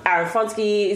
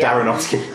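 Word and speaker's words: Aronofsky: Aaron Fonsky is doing a good job. Jennifer, sorry, can't organize the... – Darrenofsky.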